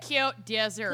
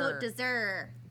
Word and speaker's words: Côte d'Azur: Côte d'Azur. – Côte d'Azur.